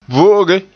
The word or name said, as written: vo'oge